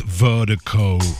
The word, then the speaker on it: Virdiko: And Vertigo is spelled V-I-R-D-I-K-O. Pronounced Vertigo. That's V-I-R-D-I-K-O.